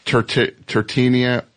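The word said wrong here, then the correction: Totino's: Totinos. – Totinos.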